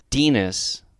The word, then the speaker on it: Denis: Dennis. Rhymes with penis. His name is actually about D-E-N-I-S, but he's going to make it rhyme with penis.